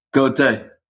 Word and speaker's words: Gotay: Gotay.